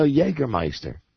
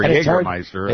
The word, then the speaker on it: Jägermeister: And you know, Jägermeister, it's the soft J.